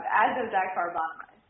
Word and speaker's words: azodicarbonamide: Azodicarbonamide.